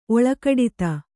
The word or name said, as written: ಒಳ್ಗುಡುತೆ